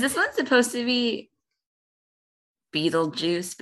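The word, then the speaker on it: Betelgeuse: Apparently, it's pronounced Beetlejuice.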